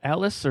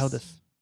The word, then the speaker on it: Aldous: Is the D silent? Is it Alice or Aldous? – Aldous? – How do you say it? – You can call me Al. – I will. – Yes. – I will. – The D's not silent.